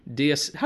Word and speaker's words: diaspora: He'll be specializing in African American and African diaspora.